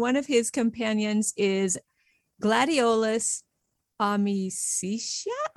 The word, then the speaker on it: gladiolus: And the first word, gladiolus, the accent is on the letter I in Latin.